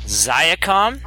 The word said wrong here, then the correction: Xiaomi: Nope. – That's different. It's Xiaomi. – I think it's Xiaomi.